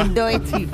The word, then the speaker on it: Doechii: A ver, ¿esto cómo se pronuncia? – Doechii. – Muy bien.